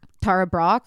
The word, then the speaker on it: Tara Brach: I'm like reading Tara Brach. How do you say her name? – Tara Brach.